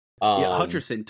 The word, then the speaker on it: Hutcherson: Hutcherson.